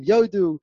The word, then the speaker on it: Yodu: like Hodu, like became Yodu, until it was actually corrected that no, the pasuk is Yodu Lashem Chasto of Niflotav Livnei Adam.